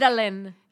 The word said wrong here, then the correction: mandelic: Is that how you say it? Mandelic. Mandelic. – Mandelic. That's the Mandelic.